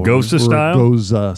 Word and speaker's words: Gose: This would be a Gose style. Goza style, G-O-S-E.